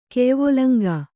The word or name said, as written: ಕೆಯ್ವೊಲಂಗಾ